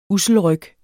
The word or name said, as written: usselryg